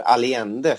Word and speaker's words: Allende: Jag tror Allende är det korrekta spanska uttalet om jag har lärt mig rätt i skolan. – Ja, jag tror att i Sverige säger man Allende. – Okej, dubbel L blir ju J och så vidare.